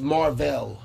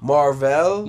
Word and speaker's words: Mar-Vell: No, that his name is Mar-Vell. – Mar-Vell? – Mar-Vell. It's spelled M-A-R with a dash in the middle. The little yeah. – Vel, V-E-L.